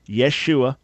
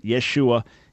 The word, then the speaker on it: Yeshua: So the proper way that the Hebrew or Aramaic name of Jesus was originally was Yeshua. That would have been the right pronunciation, Yeshua. If you want to get technical, Yeshua with an ion at the end. But the important part is the Yeshua.